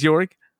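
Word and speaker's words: Jörg: It is Jorg. – That's how you pronounce that name.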